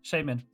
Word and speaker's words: Shaymin: Shaymin? – Shaymin.